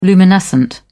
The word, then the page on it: luminescent: British English